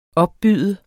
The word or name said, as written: opbyde